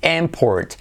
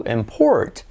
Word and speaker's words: import: Y como E, import. Sin embargo, como verbo sería to import.